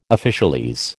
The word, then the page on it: officialese: American English